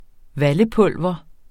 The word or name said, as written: VALLEPULVER